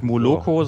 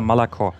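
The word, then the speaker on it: Malako (молоко): Im Russischen wird kein O ausgesprochen, außer es ist betont. Und darum hat man pro Wort immer nur maximal ein O. Also das heißt auch nicht Moloko, sondern Malako.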